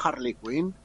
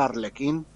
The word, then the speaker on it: Harley Quinn: un señor muy indignado diciendo que, que, lo habían traducido mal, que lo pronunciaban Harley Quinn y se pronuncia Arlequín porque es Arlequín y es como ya, pero que no se pronuncia así.